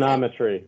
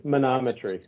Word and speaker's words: manometry: He, I had monography. I had, am I saying that right? – Monography? Manometry. Manometry. Manometry. Yeah.